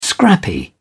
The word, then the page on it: scrappy: British English